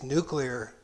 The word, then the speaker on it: nuclear: Yeah. Nuclear. It's pronounced nuclear. – No, it's not. It's nuclear. And we had a president who did not know how to pronounce it. Doesn't he have somebody on his staff which would correct him and say, it's nuclear, President Bush.